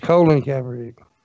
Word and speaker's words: Colin Kaepernick: You're saying his name wrong. It's Colin Kaepernick.